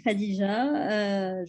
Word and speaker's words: Khadija: Alors, je suis Khadija, je suis tunisienne.